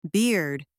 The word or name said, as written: beard